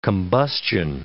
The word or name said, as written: combustion